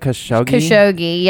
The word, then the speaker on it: Khashoggi: So I think you guys also touched on this last Thursday, but Saudi journalist Jamal Khashoggi, did I say that right? Khashoggi, yes.